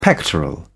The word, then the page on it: pectoral: British English